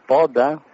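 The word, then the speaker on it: Podda: My name is Poda.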